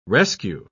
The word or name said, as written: rescue